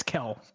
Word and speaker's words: Kel: It's not Kai. It's Kel, right? K E L. K-E-L? Okay.